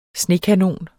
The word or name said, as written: snekanon